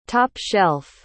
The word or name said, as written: top-shelf